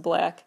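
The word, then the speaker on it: Blxst: Black, the rapper, whose name is spelled with a six instead of a B, but it's pronounced Black, and he basically has decided to promote his new EP through the most unique marketing campaign I may have ever heard of.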